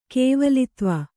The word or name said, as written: ಕೇವಲತ್ವ